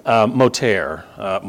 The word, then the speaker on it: Motyer: Moter, Mike, that's apparently how you pronounce the guy's last name. The quote, signs of his sovereignty, is from a guy named Alec Moter, M-O-T-Y-E-R, if you want to look him up.